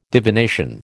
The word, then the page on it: divination: American English